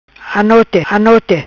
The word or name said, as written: Anote anote